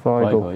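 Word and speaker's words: Weigl: It'll be more likely Julian Weigel. Have I pronounced that correctly? – Weigel. – Weigel. – Yeah.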